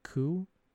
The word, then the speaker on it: Ku: I think I always say it clue, but it's like coup, coup, clue.